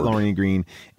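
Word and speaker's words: Lorne Greene: And so he'll be that Lorne Green. And it's Lorne Green. The E is decorative.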